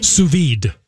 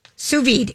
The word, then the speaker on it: sous vide: Sous vide. – Sous vide.